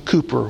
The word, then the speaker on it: Cowper: Well, the writer of all those hymns was William Cowper, C-O-W-P-E-R. It looks like Cowper, but he pronounced his name Cooper, William Cowper.